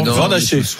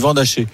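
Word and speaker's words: Van Assche: Vandache. On dit Vandache. – Je sais pas pourquoi, depuis aujourd'hui, l'entourage a décidé de changer la prononciation, alors qu'au club, depuis toujours, vous avez toujours été Vandache.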